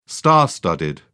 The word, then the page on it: star studded: British English